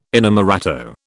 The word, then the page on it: inamorato: British English